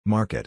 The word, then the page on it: market: ar